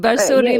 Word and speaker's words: Barsoray: The one we're doing at the moment is Barsoray. Is that how you say it? – Yes, correct. Barsoray yes. Megha, Megha, Barsoray yes.